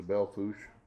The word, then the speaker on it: Belle Fourche: The Belle Fourche. – Okay, and how's that spelled? – BelleFourche. – And they don't pronounce the R. – No, it's a French pronunciation.